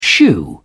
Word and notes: shoe: British English

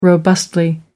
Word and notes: robustly: American English

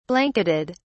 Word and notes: blanketed: British English

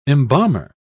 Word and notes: embalmer: British English

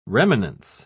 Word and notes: remanence: British English

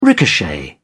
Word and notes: ricochet: British English

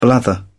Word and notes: blather: British English

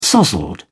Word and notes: sozzled: British English

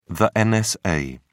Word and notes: nsa: British English

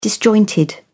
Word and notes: disjointed: British English

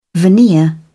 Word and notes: veneer: British English

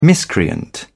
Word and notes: miscreant: British English

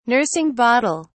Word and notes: nursing bottle: British English

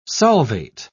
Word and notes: solvate: British English

